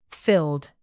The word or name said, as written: filled